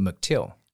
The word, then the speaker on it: McCTILL: 哎，淡如姐好，呃，我们的英文叫做 McTill 啊，McTill 好，你看我就知道我自己不要乱念哈。